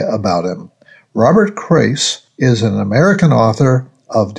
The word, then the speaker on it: Crais: Well, one of my favorite authors in this genre is Robert Crace. That's spelled C-R-A-I-S.